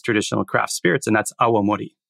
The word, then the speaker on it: awamori: And it's a four-syllable word rather than a two-syllable word. So it's a bit more cumbersome, but I think maybe a little easier to say correctly. It's awa plus mori, so awamori.